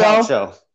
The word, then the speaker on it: Calcio: It's pronounced Calcio. – caucho, just so you know.